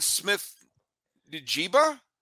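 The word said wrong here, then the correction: Smith-Njigba: Yeah, so you put an emphasis on the N and then everything else is just pronounced the way it is. But Kanan Smith Njigba, yes.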